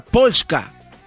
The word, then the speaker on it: Polska: El, nombre Polonia eh, se escribe, se pronuncia Polska. Polska. Polska.